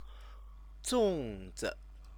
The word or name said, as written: zongzi